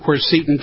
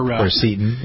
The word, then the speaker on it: quercetin: Well, quercetin, quercetin, depending on where... – quercetin, yeah. – Yeah, it depends on what school you went to.